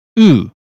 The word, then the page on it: blue: British English